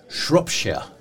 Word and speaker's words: Shropshire: Shropshire. – Ja. – Mm. Det tror jag är korrekt så uttalat.